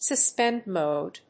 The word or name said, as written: suspend mode